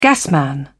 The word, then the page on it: gasman: British English